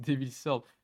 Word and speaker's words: Devil's Third: j'ai, j'ai, les, flashbacks là, t'as prononcé Devil's Third. c'est Devil's Third.